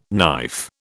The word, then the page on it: knife: British English